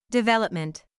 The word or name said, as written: development